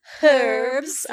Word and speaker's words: herbs: Lots and lots of herbs. I'm so sorry to anybody who wants me to pronounce it with an H. I'm going to say herbs.